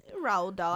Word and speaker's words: Roald Dahl: So it is based on a Roald Dahl book. Did I say that correctly? Roald Dahl? – Roald Dahl. I think you got it.